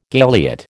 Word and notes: galiot: American English

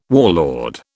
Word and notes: warlord: British English

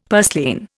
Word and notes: purslane: Indian

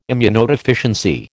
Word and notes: immunodeficiency: American English

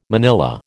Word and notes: manilla: American English

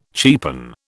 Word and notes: cheapen: British English

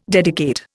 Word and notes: dedicate: Indian

